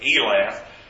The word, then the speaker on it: Elath: recovered Elah to Syria and drove the Jews from Elath and the, I'm sorry, I pronounced that incorrectly, Elath drove the Jews from Elath, and the Syrians came to Elath and dwelt there to this day.